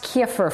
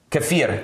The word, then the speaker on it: Kefir: It's pronounced Kiefer for us. – Kefir.